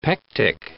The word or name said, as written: pectic